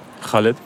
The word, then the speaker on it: Khaled: Farid Bang hat mit French Montana und Khaled, ich weiß nicht, spricht man ihn so aus? – Khaled. – Den Song Maghreb Gang gemacht.